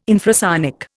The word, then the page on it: infrasonic: Indian